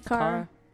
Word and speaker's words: Cara: He had a new project to come out, Light of Mine, and this single is Babies featuring Alicia Cara. Is that how you pronounce her name? – Cara.